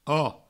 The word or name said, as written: oco